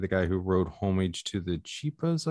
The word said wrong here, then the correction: Chiapas: Is that the correct pronunciation, Keith? – Chiapas. – Yeah, Homage to the Chiapas.